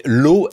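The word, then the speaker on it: Law: Moi, je dis le système de l'as parce que, notamment dans les poèmes, dans tous les poèmes satiriques, les chansons de l'époque, on fait rimer l'as avec as. Donc, ça atteste de la prononciation du temps.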